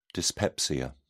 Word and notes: dyspepsia: British English

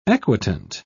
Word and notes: equitant: British English

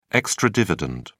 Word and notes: extra dividend: British English

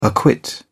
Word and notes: acquit: British English

